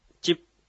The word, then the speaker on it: チップ: はい、チップ。 – チップ。 – はい。チに聞こえるかもしれませんが、チップという、チの後にですね、口を閉じる発音です。